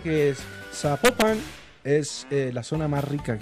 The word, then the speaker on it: Zapopan: Oiga, nos escriben, escriben los oyentes y que no se, pronuncia Zapopan, sino que se pronuncia Zapopan.